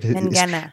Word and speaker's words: Mangina: The E is very deceptive. Mangina. It wants to soften the G, but the G's like, up yours, mate. I'm a hard G.